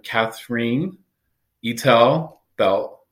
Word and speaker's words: Katherine Eitel Belt: We have Katherine Eitel Belt on the program. Did I say that correctly? – Well, it's Katherine Eitel Belt, but close enough.